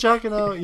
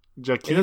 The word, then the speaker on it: Giacchino: Is it Giacchino? You know, it is. You're mostly Italian. You should know this.